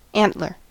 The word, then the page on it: antler: American English